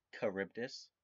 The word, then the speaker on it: Charybdis: Well, for those of you listening, Steve, real fast, before we jump anywhere, it's pronounced Charybdis.